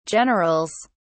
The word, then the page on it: generals: British English